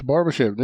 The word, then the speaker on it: Barbashev: Nej, det är du som säger rätt. Barbashev, det är som man säger.